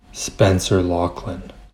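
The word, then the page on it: Spencer Loughlin: English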